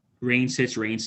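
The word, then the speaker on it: Wraneschitz: Reinschitz.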